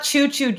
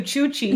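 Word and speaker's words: cuchi-cuchi: Obviously, we know her for her signature phrase, choochie choochie. – No. Coochie, no. Coochie. – Coochie, coochie. – Not choo-choo, choochie.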